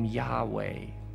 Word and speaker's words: Yahweh: Now, some pronounce the name Yahweh or Yahweh, although in many traditions, the name isn't even pronounced because it's considered so sacred, so mysterious, so holy.